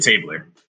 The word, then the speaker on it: Tabler: It's actually Tabler. Like Tabler with an R at the end.